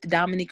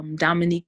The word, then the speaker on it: Domonique: So my YouTube channel is Study with Dominique Roberts. That's Dominique, DOMONIQUE.